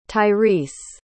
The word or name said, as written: tyrese